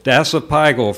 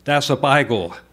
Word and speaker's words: dasypygal: Dasypigal, from Greek, dasus, hairy, plus pyga, rump, buttocks, four syllables. Dasypigal.